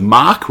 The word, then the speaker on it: Mach: Oh, also we get the proper pronunciation of Mach, which is apparently Mac. So like Mac 1 or Mac 0. I am against this and will continue calling him Mach because that's what they call this.